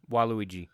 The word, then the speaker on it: Waluigi: Is that how you pronounce it? Waluigi? – Waluigi.